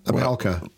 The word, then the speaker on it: alpaca: You didn't say alpaca. Alpaca.